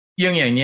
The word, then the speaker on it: Jungian: did I pronounce it right earlier, Jungian? – Jungian, yeah. Yes, my aunt Marion Marion Woodman was a mythopoetic writer and author, Jungian author.